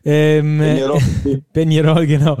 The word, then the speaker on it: Peñarol: Dein Club Atlético Peñarol, ich hoffe, ich habe das richtig ausgesprochen mit diesem Enje. Peñarol, genau.